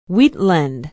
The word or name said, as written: wheatland